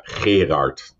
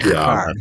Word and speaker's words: Gerard: I'll say it once the way the Dutch pronounce it. – Okay. – It's actually Gerard. – Oh my God. – No. Gerard.